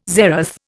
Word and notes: zeroth: Indian

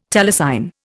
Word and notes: telecine: Indian

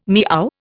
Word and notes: meow: Indian

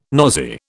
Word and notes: nosy: British English

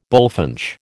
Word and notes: bullfinch: American English

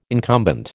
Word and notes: incumbent: American English

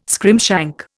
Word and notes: scrimshank: Indian

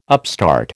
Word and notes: upstart: American English

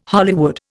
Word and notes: Hollywood: Indian

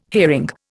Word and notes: hearing: Indian

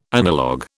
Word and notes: analogue: British English

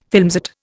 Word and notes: filmset: Indian

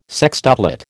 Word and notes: sextuplet: American English

